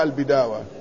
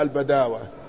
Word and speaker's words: البداوة: قال والبداوة والبداوة الإقامة، قال وهي بكسر الباء عند جمهور أهل اللغة البداوة، وقال أبو زيد هي بفتح الباء البداوة.